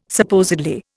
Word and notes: supposedly: Indian

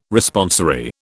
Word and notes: Responsory: British English

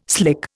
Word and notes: slick: Indian

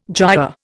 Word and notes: giaour: Indian

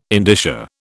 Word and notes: indicia: British English

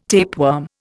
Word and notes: tapeworm: Indian

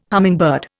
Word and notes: hummingbird: Indian

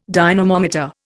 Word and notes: dynamometer: Indian

